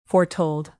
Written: foretold

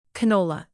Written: canola

